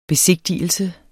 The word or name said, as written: besigtigelse